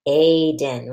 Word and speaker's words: Aiden: Listen to the long vowel sound in the word Aiden. Ready? Aiden. It's A. It's the long vowel A, and it has the same vowel team as in train.